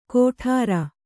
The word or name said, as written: ಗೊಟರ್